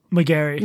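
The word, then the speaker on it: Magary: Do you remember the SF Gay piece by Drew Maggery? Maggery? How do you pronounce his name? – McGarry. – McGarry. That was quite the butchering.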